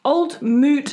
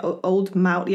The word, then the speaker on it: Old Mout: So today I'm drinking Old Moot Cider. I think that's how you actually pronounce it. I know people say Old Mout. Yeah, it says it on the back phonetically.